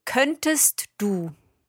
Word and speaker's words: könntest: Könntest du. – Könntest du. There we are pronouncing that T, könntest du.